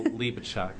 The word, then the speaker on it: Hlebechuk: My name is Michael Libacek. How do I spell that? Because it's a challenge to spell. H-L-E-B-E-C-H-U-K.